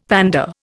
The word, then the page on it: pander: Indian